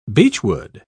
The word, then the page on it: beechwood: British English